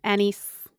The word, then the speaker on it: anise: Licorice, anise. Is that the way it's pronounced? – Anise. – Yeah, I don't know how to actually pronounce that. – Otherwise, you say anus, or you're too close to anus.